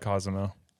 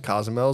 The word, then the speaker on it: Cozumel: I like Cozumel. – Cozumel or Cozumel? – Whatever. – You like Cozumel. I like Cozumel as well. It's like a, what did you say? – Cozumel.